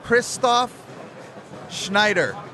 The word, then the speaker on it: Christoph Schneider: All right, Christoph Schneider.